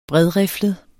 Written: bredriflet

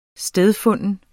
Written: stedfunden

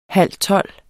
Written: halvtolv

